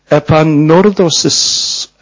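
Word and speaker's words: 에파노르도시스 (ἐπανόρθωσις): 에파노르도시스. 노르 할 때 이게 비침이 있는 것은 발음을 세게 해라 이런 뜻이거든요.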